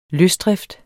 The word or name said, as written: løsdrift